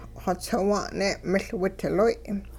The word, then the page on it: xotsiwa'n-ne' miL-widloy': Hupa